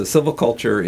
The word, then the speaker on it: silviculture: The first question I want to ask you is, what the heck is silviculture, and have I pronounced it correctly? – You pronounced it very close. So silviculture is just the art and science of growing trees.